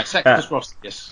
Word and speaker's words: Sextus Roscius: The series begins with the trial of Sextius Roscius, which is a very sort of. – I'm going to be fluffing the pronunciations all the way. Sextus Roscius.